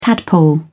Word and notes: tadpole: British English

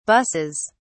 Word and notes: buses: British English

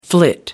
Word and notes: flit: American English

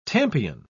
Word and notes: tampion: British English

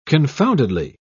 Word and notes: confoundedly: British English

